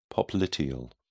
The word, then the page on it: popliteal: British English